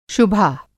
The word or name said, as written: शुभा